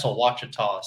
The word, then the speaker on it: Ouachita: It looks like it's spelled like Ouachita. – Yeah. – And I asked Steve Kennedy. I was like, I'm fishing this place called Ouachita. He's like, that's Ouachita, son.